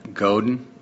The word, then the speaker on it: Godin: One, I mispronounced his name in the real intro. It's Seth Godin.